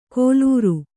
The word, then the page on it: ಕೋಲೂರು: kōlūru